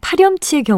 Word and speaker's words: 파렴치: 그렇다면 파렴치의 경우는 어떨까요? 파렴치 역시 한자어이긴 하지만 일반적인 현실 발음이 파렴치이기 때문에 파염치로 표기를 정한다면 표기와 발음간의 불일치를 설명할 수 없게 됩니다.